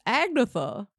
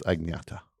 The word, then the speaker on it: Agnetha: Oh, is that how you pronounce her name? – Agneta? – I guess my dumb galoot of an American brain would always read it as Agnetha. – Yeah, it's Agneta.